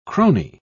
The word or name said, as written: crony